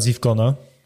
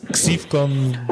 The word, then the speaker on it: Zivcon: Teraz przeskoczymy płynnie na Zivcona. Czy dobrze wymawiam Zivcon? – Tak jest, dobrze. – Doskonale. Pamiętajcie Zivcon, nie X33FCon, albo Xivcon.